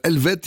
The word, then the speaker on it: ELVE2T: Le groupe s'appelle Helvet, il s'écrit E-L-V-E-2-T, hein, si vous le cherchez, on pourrait se tromper.